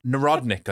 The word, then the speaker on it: Narodnik: He was actually from a Nardonic family. – Can we call him Stefan? – Narodnik, I think is the pronunciation. Narodnik, yeah, sorry.